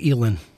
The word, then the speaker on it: Elin: C, Tiger and, how do you say her name? – Elin, I think. – Elin? – Yeah. – I believe it's Elin.